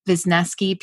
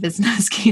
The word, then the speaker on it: Visnesky: Visnesky PGH and Visnesky is spelled V I S N E S K Y.